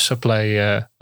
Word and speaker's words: SuPlay: Then it's great for people who are doing the exact same sort of course, same learning objectives, that they can use SuPlay as well. – Yes, correct. – Now I'm saying it correctly. – Yes. Perfect.